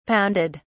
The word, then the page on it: pounded: British English